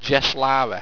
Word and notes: jeslara: Owstwecian

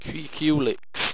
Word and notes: kreackulix: Owstwecian

